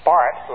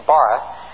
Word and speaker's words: Barth: By the way, his name is properly pronounced Barth rather than Barth, but you do hear both of them.